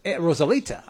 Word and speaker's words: Rosalita: Before that, Letter to You, Rosita, come out tonight. Rosalita, I'm sorry. I took away a syllable from her. That was uncool of me.